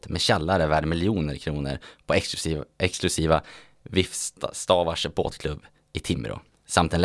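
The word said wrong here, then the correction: Vivstavarv: Är det så man uttalar det? Vifstavarvs, tack.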